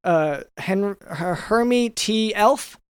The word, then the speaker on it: Hermy T. Elf: This is Mr. If I'm pronouncing this right, Hermy T. Elf.